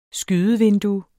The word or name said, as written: skydevindue